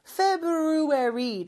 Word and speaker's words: February: 왜 B 다음에 R은 발음 안 하세요? February 라고 하는 경우는 거의 없어요. 그 R은 그냥 묵음 처리해 주시면 돼요.